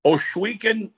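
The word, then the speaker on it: Ohsweken: And you told me, now, I want you to help me with this pronunciation because I like to learn. Oswiecan? – Oswiecan, Ontario, which is classified as Six Nations.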